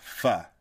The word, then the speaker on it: faux: I pronounce it the way I see it, and I need to remember it's faux.